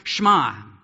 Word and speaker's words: Shema: It looks like Shema, but it's actually pronounced Shema.